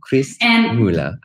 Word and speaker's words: Quist-Møller: So Selma, can you say your last name properly for me, please? – You did so well. – Did I do good? – So it's Selma Quist-Møller. – Selma Quist-Møller. I did okay.